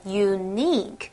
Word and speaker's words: unique: OK, 要注意它的发音哦，重音要发在第二个音节 unique，它就是表示很独特的啦，这样子的意思。